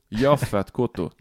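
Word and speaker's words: Yaphet Kotto: no, det navnet som aldri klarer å uh, Klarer å uttale skikkelig. – Mm. – Men du, du, du kan det nå, vel? Jappet Kotto. Jaffet Kotto. Jaffet Kotto. Jaffet Kotto, ja. som han heter.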